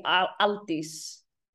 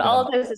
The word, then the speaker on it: Aldís: It's the Icelandic way would be Aldis. – Okay. – Yeah, but Aldis is fine. I don't mind.